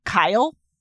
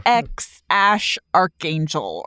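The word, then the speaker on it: X Æ A-12: It's actually like X Ash Archangel or something like that.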